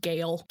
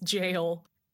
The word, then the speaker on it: gaol: People don't tend to tell you that gaol is spelled, it's pronounced jail.